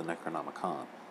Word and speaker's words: Necronomicon: Yeah, he's a Necronomicon. It's the Necronomicon.